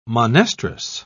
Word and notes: monestrous: British English

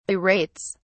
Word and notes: berates: British English

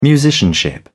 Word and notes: musicianship: British English

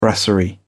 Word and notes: brasserie: British English

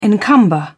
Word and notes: encumber: British English

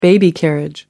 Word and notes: baby carriage: American English

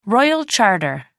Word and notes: royal charter: American English

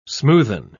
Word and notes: smoothen: British English

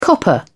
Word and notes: copper: British English

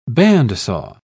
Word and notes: bandsaw: British English

